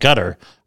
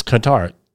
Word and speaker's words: Qatar: I've always heard it pronounced gutter, but it's Qatar, Q-A-T-A-R, hit by missiles.